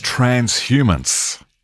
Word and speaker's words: transhumance: Okay, your word today is transhumance. – Trans what? – Transhumance. – Transhumance? A-N-T-S? – No, transhumance. H-U-M-A-N-C-E. Oh.